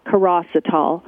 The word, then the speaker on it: chirositol: It's spelled C-H-I-R-O-S-I-T-O-L, Kerecitol.